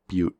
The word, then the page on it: butte: American English; English pronunciation of the California county of Butte